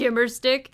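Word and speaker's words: gimer stick: Yoda would throw in the his gammer stick, or what's it called? – The gimmer stick. – Did I pronounce it? Gimmer stick.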